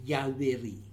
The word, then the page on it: Yaweri: new form of Averi